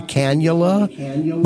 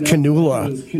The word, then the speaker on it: cannula: Well, the thing that you put in your nose for the oxygen is called a cannula. But before I knew how to pronounce it, I thought it was canula, which really sounds like, leave the gun, take the canula or something.